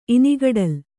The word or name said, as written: ಇನಿಗಡಲ್